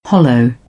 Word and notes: hollow: British English; əʊ

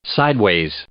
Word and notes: sideways: adverb

